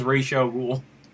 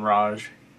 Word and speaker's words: Ra's al Ghul: Yeah, and he does actually say his name right. He says Ra's al Ghul, where everyone calls him Raj.